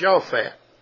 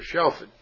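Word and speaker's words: shofat (שָׁפַט): Defend there is 8199 in the Hebrew, and it is pronounced shofat or shofet.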